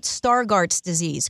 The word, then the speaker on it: Stargardt's disease: Now, tell everybody, it's true, you do have, and am I pronouncing this right, Stargardt's disease, correct? – Yeah, that's right. Yeah.